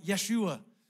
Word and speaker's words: Yeshua: We say Jesus, it probably in his time would have been pronounced something much more like Yeshua, just smashing together the name Yahweh and the Hebrew word for saves.